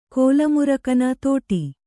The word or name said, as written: ತೋಡಿ